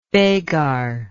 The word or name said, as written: begar